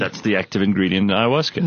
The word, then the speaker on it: ayahuasca: It's, are you ready for ayahuasca? – By the way, how complicated is it to spell ayahuasca?